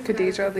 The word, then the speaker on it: Kadajah: Khadija Levy. – Can you spell it? – K-A-D-A-J-A-H. – L-A-I-D-L-E-Y.